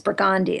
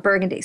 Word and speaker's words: Burgundy: It's Burgundy, it's not Burgundy.